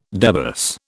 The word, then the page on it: debus: British English